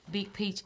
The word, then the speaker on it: Big Peach: My name is Big Peach, emphasis on the P.